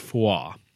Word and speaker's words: fuath: It's pronounced FYI. – This Celtic stuff, it's great, but I... – Guys, I'm not mad at you, but it's real tough. – To try and get this right. It's spelled F-U-A-T-H, and Fwarf. – I think it's pronounced foie.